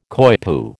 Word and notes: coypu: American English

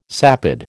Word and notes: sapid: American English

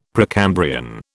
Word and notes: Precambrian: British English

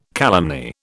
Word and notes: calumny: British English

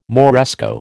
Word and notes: Moresco: American English

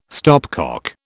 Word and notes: stopcock: British English

